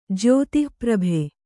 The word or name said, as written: ಜ್ಯೋತಿಃಪ್ರಭೆ